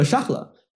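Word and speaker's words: Charles: 那其实查理啊，其实是这个法语的这个沙赫，呃，所以说有地方翻译成夏尔，其实更加更更加合适啊，就更加适合这个更加符合读音。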